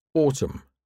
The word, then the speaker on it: autumn: The N isn't pronounced when it comes after M at the end of a word. Solemn, hymn, autumn, column.